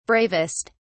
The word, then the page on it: bravest: British English